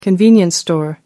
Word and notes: convenience store: American English

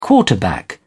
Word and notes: quarterback: British English

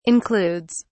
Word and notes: includes: British English

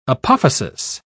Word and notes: apophysis: British English

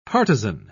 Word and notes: partizan: British English

